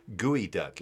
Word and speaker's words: geoduck: And if the chef said like gooey duck, you're like, the fuck is gooey duck? Turns out it's spelled geoduck, geoduck.